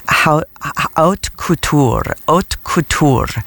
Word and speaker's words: Haute Couture: Like Haute Couture is the way that that's pronounced.